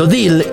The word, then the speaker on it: Odile: Desde Chica de Oficina de Joe Menos suenan fragmentos a cargo de Pablo Durio, Odil, que se pronuncia O-Dil,